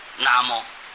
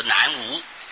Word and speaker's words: 南无: 应当要知道这是南摩呀，有些地方写作那是这个拿的拿，或者是拿东西的拿。摩他用这个言字旁做一个墨字，那个摩南摩啊，写作南摩，他就不至于念作南无，就不会念作南方没有啊。